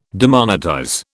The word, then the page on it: demonetize: British English